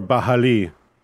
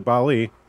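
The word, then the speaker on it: Baali: Or Bahali. Or Bali. However you'd like to pronounce it. We pronounce it Bali. Don't tell us otherwise.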